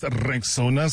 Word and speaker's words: Rexona: It's pronounced Rexona, simple, strong, and easy to remember.